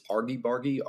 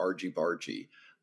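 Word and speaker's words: Argybargy: Uh, I still don't know if it's Argy Bargy or Argy Bargy uh, if it's a hard G or a soft G.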